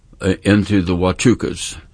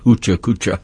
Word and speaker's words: Huachuca: And so I wanted to get to know more about it, so I went down and I went into the Huachucas, spelled H-U-A-C-H-U-C-A, pronounced Huachuca, not Huchacucha.